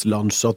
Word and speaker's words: Lancia: Og så har jeg også følget med på at Lancia, som uttales Lancia, tror jeg, mm.